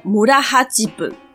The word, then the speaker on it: 村八分: 村八分日文念作 murahajibu，它是村落中的一个隐性制度。